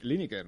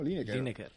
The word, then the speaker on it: Lineker: Bueno, yo no sé, yo siempre he dicho Gary Lineker, ¿no? – Lineker.